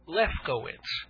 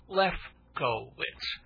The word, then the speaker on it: Lefkowitz: Dave or David Lefkowitz. Okay, that's four or five syllables. Lefkowitz.